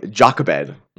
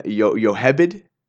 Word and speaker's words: Jochebed: Our last mom that we're going to talk about today is, in English, we would pronounce her name Jochebed, which I believe more appropriately her name would have been Yochebed.